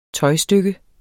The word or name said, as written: tøjstykke